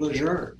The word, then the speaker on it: Lejeune: probably the correct pronunciation is Lejeune.